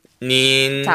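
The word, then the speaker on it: 닌 (您): 닌.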